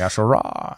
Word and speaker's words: Al-Sharaa: Al-Sharaa. Ahmed Hussein Al-Sharaa. Yeah. You had it. – Yeah. There's just so many A's. I like want to bring it out. Like, Al-Sharaa.